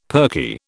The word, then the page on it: perky: British English